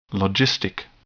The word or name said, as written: logistic